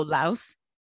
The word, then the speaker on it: Laos: Am I pronouncing that right? From Laos? Laos? – Oh, Laos. – Okay. Laos is right.